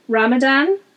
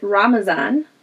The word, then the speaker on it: Ramadan: Which is a huge contrast to the next one that I'm going to mention, which is Ramadan. Or, as my Kurdish friends pronounced it, Ramazan. – Which I think is more Persian influence, is Ramazan.